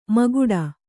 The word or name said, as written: ಮುಕ್ಕೋಟಿ